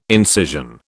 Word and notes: incision: British English

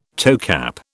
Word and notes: toecap: British English

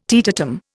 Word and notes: teetotum: Indian

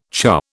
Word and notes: churr: British English

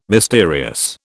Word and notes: mysterious: British English

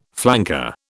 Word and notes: flanker: British English

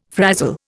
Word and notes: frazil: Indian

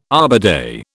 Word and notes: Arbor Day: British English

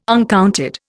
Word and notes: uncounted: Indian

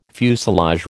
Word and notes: fuselage: American English

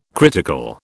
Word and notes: critical: British English

